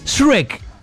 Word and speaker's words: Shrek: Shrek, ¿cómo se dice? – Shrek.